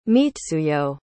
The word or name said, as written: mitsuyo